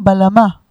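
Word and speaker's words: bağlama: בגלמה. – בגלמה. – אבל כתוב באינטרנט, אני בדקתי, שלא מבטאים את הגימל, אומרים בלמה.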